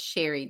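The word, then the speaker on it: Cherie: And friends, it's C-H-E-R-I-E Nettles. It's Sherry Nettles.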